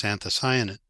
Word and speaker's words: anthocyanin: And they integrated several genes that produce anthocyanin into a tomato background.